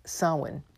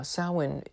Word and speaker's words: Samhain: Samhain um, and it looks like Samhain, but that's not how you say it. You know how Gaelic is; it doesn't resemble anything in reality.